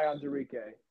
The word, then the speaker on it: Onwuzurike: Oh, it's Levi Onwuzurike.